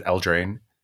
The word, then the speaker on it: Eldraine: Is it Eldraine? – Yeah, it's the drain in Spanish is what someone told me.